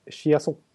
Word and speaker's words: Chiasson: Alex Chason. – Mm -hmm. – Eller Alex Chiasson, om man ska köra fransk-kanadensiska, i Dallas.